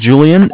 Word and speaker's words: Julian: My name is Julian. It's J-U-L-I-A-N.